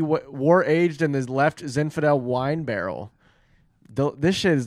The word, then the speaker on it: Zinfandel: That is a left Zinfandel wine Zinfandel.